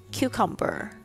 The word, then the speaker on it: cucumber: Next one， Cucumber 黄瓜 Cucumber，注意一下它里面虽然有两个 U，但是它的那个 U 发音都不一样，第一个是发 U，另外一个是发 A 的声音。